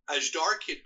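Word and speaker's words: Azhdarchid: It's called, let's see if I can pronounce it here, the Ajdarkid group of pterosaurs are named after the Aji Dahaka, the Ajdarkid. – Wow, that seems right.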